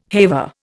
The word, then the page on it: haver: Indian